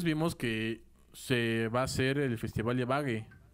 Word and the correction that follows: Ibagué: ¿no? Ibagué. Perdón la pronunciación, amigos, que se me gasta mi colombiano, entonces.